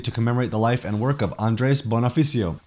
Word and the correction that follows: Bonifacio: Bonifacio. I think I'm mispronouncing that the whole time.